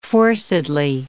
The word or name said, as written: forcedly